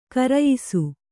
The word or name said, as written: ಘೇರಾಯಿಸು